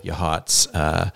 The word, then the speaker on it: Yachats: Beautiful Yahats, spelled Yachats. – You'd never know how to pronounce it unless you went there and someone told you.